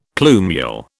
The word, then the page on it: plumule: British English